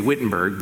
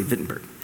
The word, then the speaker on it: Wittenberg: We would say Wittenberg, but they pronounce their W's as V's, so I'm going to be proper and say Wittenberg, Germany.